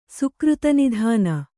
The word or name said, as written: ಸುಕೃತನಿಧಾನ